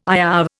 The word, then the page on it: IOW: Indian